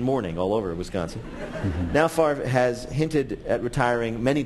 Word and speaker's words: Favre: The man who confounded opposing teams' defensive lines by spelling his name as if it pronounced Favre, but pronouncing it Favre.